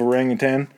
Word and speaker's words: orangutan: I'm just knowing now that you actually have to pronounce the N on the end of orangutan.